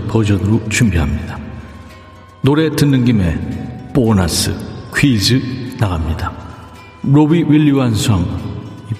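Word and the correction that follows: Robbie Williams: 로비 윌리엄스와 함께 이 노래를 부른 배우가 있죠.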